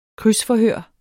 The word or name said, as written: krydsforhør